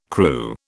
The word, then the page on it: Kroo: British English